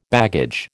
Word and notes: baggage: American English

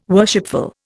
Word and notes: worshipful: Indian

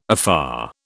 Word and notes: afar: British English

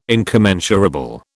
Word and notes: incommensurable: British English

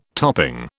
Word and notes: topping: British English

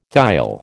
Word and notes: thiol: American English

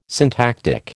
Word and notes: syntactic: American English

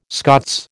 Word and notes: Scots: American English

